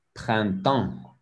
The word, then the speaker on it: printemps: Printemps. En. Pas le P, non. Le S, non.